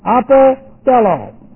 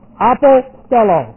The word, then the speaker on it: apostello: Apostello. This is one word. It's a long O. Apostello. The other is pimpo. P-E-M-P, long O.